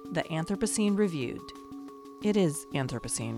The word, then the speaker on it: Anthropocene: And I've heard Anthropocene, I say Anthropocene, but I also come from the American South, so I put the emphasis on the first syllable almost all the time.